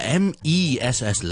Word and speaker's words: mess: 但 MESS 呢我们就会读成是 ,m-a-a-a,okay? One more time, the first one is, the second one is, so, Mass 是 M-A-S-S, M-E-S-S 就是 mess, mess, mess, mess, what a mess, 那个 mess, okay. 那我们再给多几个例子，譬如 B-A-N 和 B-E-N 的分别也都是同一样的。